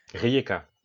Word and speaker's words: Rijeka: Euh, ils l'ont emporté face, tu me reprendras sur la prononciation, je crois que c'est Rijeka. – Rijeka.